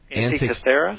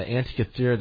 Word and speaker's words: Antikythera: Antikythera? – Yeah, the Antikythera device.